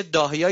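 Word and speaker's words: الضاحية: اونا میدونن که استراتژی دارن، استراتژی داهیا یا زاهیه. این زاد رو د تلفظ میکنن تو انگلیسی، مثل رمضان میگن رمضان.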